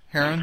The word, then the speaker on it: Haran: So his brother is named Haran, H-A-R-A-N. How do you say it? Haran? Haran? – Haran, yeah.